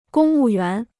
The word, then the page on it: 公务员: gōng wù yuán